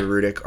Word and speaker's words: Rudick: Rudick.